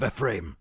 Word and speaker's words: Ephraim: Forest of Ephraim. – Ephraim. – Ephraim. – Ephraim. Like it's three syllables.